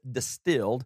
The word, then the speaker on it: DSTLD: I got a new pair of jeans from a new company called Distilled, and they spell it a little different, you know, the thing without the vowels in it, but a little different than that. D-S-T-L-D. D-S-T-L-D. They pronounce it Distilled, and they make some jeans.